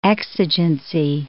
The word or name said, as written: exigency